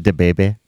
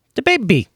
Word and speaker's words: DaBaby: DaBaby, am I saying this right? DaBaby? – DaBaby.